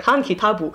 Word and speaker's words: Han Kitab: So he wrote this book called, and they say it, the way they pronounce it is like Han Kitabu.